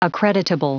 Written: accreditable

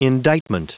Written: indictment

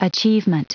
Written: achievement

